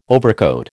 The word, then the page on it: overcoat: American English